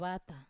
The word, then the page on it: vata: Wamesa